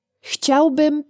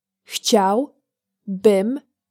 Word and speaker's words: chciałbym: In Polish, I, mail, would like to send this to Italy is Chciałbym to wysłać do Włoch. Chciałbym to wysłać do Włoch. Let's break it down by syllable. Chciał, bym, to, wy, słać, do, Włoch.